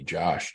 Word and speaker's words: Josh: Josh, J-O-S-H, at Be Awesome, but it's spelled B-E-A-U, S as in Sam, M as in Mary, and I'd love to talk to you.